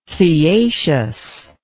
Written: theaceous

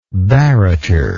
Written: barretor

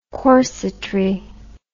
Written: corsetry

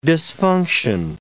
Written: dysfunction

